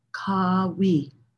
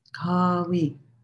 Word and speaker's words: Qawi: And the word is Kawi, Kawi. It's spelled or transliterated Q-A-W-I, but you could think of it almost like, K-A-W-E-E, Kawi.